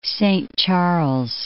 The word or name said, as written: Saint Charles